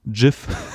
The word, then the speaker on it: GIF: hat nämlich gesagt, es wird mit einem weichen G ausgesprochen. GIF.